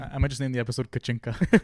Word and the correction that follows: Kachina: Kachinka okay. Or Kachina? – It's Kachina.